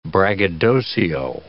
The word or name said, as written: braggadocio